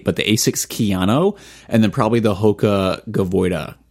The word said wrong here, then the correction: Gaviota: Am I saying that right? I have no idea. – Gavoida? – Is that a... – Gaviota. – Gaviota.